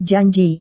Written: janji

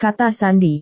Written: kata sandi

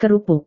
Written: kerupuk

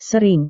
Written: sering

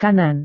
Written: kanan

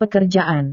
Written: pekerjaan

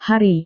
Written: hari

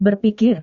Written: berpikir

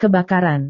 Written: kebakaran

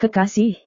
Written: kekasih

